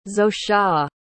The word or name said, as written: zosia